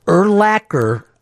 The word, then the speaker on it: Urlacher: It's the easiest name to pronounce correctly because it rhymes with his position. – I love Urlacher. – Urlacher, linebacker.